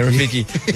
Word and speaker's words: Rafiki: Yeah, Rafiki.